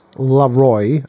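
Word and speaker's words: La'Roi: It's L-A-apostrophe-R-O-I, Leroy, I think,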